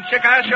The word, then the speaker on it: Chickasha: Going to march with the boys and gals in the Borden General Hospital in Chickasha, Oklahoma. And I pronounced it right.